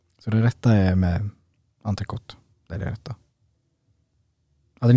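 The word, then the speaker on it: entrecôte: Det, frans, alltså, ska man det franska uttalet är entrecote.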